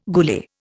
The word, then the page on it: goolie: Indian